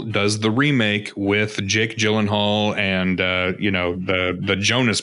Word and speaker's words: Gyllenhaal: It really is Gyllenhaal.